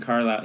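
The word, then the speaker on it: Carlisle: And you don't spell Carlyle with a Y in this case. You spell it with an I-S. – Yeah. – So a silent S in there. But Thomas John Carlyle, it is a great little poetry book.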